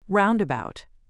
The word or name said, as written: roundabout